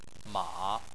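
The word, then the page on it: ma: tone 3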